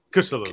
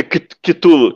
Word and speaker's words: Cthulhu: Pela glória de Cthulhu, se pronuncia assim mesmo? Cthulhu. – É, Cthulhu, Cthulhu.